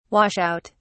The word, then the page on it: wash out: British English